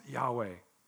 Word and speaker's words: Yahweh: And remember that capital L-O-R-D behind it is the Hebrew name of God, which we might pronounce Yahweh, "who brought you out of the land of Egypt, out of the house of slavery.